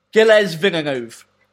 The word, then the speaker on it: Gilles Villeneuve: Gilles Villeneuve.